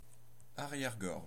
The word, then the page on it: arrière-gorge: France (Normandie); /a.ʁjɛʁ.ɡɔʁʒ/